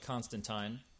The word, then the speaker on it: Constantine: Is that how you say it, Constantine? I've always said Constantine. – I've always said Constantine, and in fact, I have to force myself to remember to say Constantine. But according to Alan Moore, folks, their actual pronunciation is Constantine.